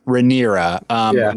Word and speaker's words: Rhaenyra: The messenger who takes, Or I'm sorry, the bard who's performing for, what is her name again? Rhaegera? I always say it wrong. – Rhaenyra.